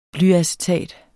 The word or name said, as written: blyacetat